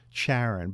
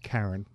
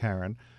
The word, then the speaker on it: Charon: You'll hear a lot of announcers say Charon, but it's Charon. Pronounced okay. – Charon.